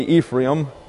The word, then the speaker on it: Ephraim: We're speaking of a man who's named Nun, N-U-N. He is a prince of the tribe of Ephraim, E-P-H-R-A-I-M. We usually say Ephraim. That's sort of an anglicized pronunciation, but it's Ephraim. His name, his given name is Hoshea. H-O-S-H-E-A, pronounced Hoshea, and that means salvation.